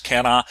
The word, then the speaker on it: Canha: I think it's pronounced Canha. Um, Canha.